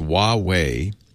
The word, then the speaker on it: Huawei: People can't pronounce Huawei. It's perfectly pronounceable if you know Chinese, but if you don't, you just, is it Huawei? It's Huawei.